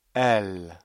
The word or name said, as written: elles